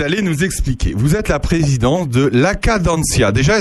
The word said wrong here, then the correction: La Cadencia: est-ce que je le prononce bien Voilà, avec un petit peu plus de légèreté ah, peut-être. La Cadencia.